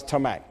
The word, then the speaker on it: tamak: The Hebrew word here that's used here is a little word we just simply pronounce tamak, T-A-M-A-K. Transliterate, write it in the English language, or you can say it as probably T-A-M-A-C-K, tamak, or however you want to say it.